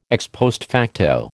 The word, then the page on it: ex post facto: American English